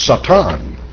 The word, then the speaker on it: Satan: Because in Hebrew, it is spelt exactly the way you see it here and pronounced almost the way that an Englishman would pronounce it today. It's Satan, and that is a adversary.